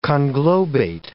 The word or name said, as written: conglobate